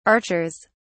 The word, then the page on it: archers: British English